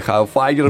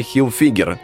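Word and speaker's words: Hilfiger: Томми Хауфайгер, у нас да. принято его называть. Эм, некоторые развернуть именно таким образом, но, однако, правильно говорить Томми Хилфигер, как и зовут, э, в принципе, основателя этого бренда.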